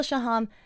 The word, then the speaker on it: Shaham: Gil pronounces their last name with the emphasis on the second syllable, Shaham, and Orly pronounces it with the emphasis on the first syllable, Shaham.